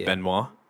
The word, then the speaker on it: Benoit: Have you noticed now that the correct way to pronounce that name is Benoit? – Is it? – Benoit. Yeah, it's Benoit.